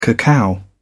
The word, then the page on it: cacao: British English